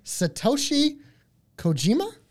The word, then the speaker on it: Satoshi Kojima: We have a singles match between Jon Moxley and Satoshi Kojima? – You did say it right, correctly.